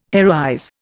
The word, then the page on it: heroize: Indian